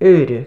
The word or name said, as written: AORADH